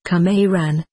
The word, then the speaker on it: Qumran: It is said that they are from Qumran. Pronounced, Qumran.